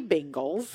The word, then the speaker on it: Bengals: The quarterback for the Cincinnati Bengals.